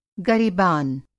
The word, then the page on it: गरेबान: گریبان